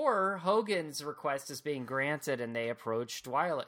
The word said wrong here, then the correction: Dwalia: Dwyla I don't know why I can't say her name. – Dwalia. – Dwalia.